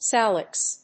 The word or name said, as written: salix